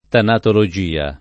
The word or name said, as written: tanatologia